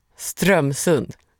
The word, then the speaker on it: Strömsund: Strömsund uttalas med betoning på första stavelsen. Strömsund. – Strömsund.